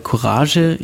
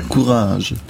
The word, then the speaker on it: Courage: Ganz wichtig, Deutsch aussprechen. – Ja, genau. Also wobei Courage, ja doch. – Das ja, ist die deutsche Aussprache. Okay. Französisch wäre Courage.